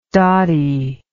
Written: dottie

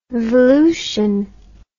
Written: volution

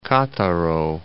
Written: Cattaro

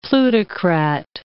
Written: plutocrat

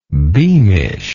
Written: beamishly

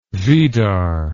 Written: Vidar

